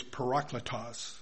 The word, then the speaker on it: parakletos: The word for the Spirit, by the way, in the book of John and other places, is parakletos. That is spelled P-A-R-A-K-L-E-T-O-S, and it's phonetically spelling.